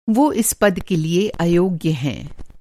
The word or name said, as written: incompetent